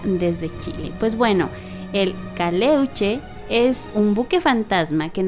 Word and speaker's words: Caleuche: Esta es la leyenda de El Caleluche. – Mm. – El Caleuche.